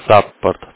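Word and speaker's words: support: Читается как саппорт, то есть S, U. Как и русская.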